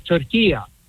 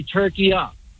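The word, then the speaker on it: Turkiye: At the end is a short A. It's not a long A. In the Arabic language, it's called turkiya. So you could say turkiya. It's not like a long A at the end. So it's a short A at the end. Very simple.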